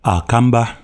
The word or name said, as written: Akamba